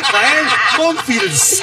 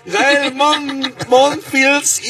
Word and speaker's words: Gael Monfils: Gael Monfils. – ¡Eso, tónalo! – ¡Ay, lo dije bien! Después de dos intentos. Gael Monfils, hijo de la inga.